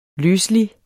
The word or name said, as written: løselig